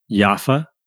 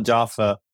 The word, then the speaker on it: Jaffa: Oh, nice. You pronounce it in Arabic. We call it Jaffa. I'll get into why we did that. But yeah, Jaffa Coffee Roaster is also known as Yaffa, the city in Palestine.